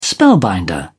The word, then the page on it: spellbinder: British English